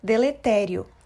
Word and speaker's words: deletério: Por ser uma proparoxítona, é acentuada em sua sílaba tônica, deletério. E o que significa?